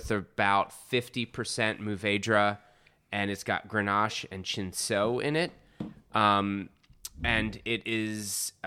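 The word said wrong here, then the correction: Cinsault: I thought it was Cinso. – So I take my knowledge of Italian pronunciation, and the C-I is chi, so that's what I did. It's not accurate, it's not correct, so, well, anyway.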